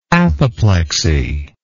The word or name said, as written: apoplectoid